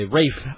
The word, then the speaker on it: Ralph: Fiennes, by the way. Rafe. – I keep doing that. Every time I know it's Rafe Fiennes, and I keep reading Ralph because it's on the paper it says Ralph.